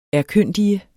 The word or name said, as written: erkyndige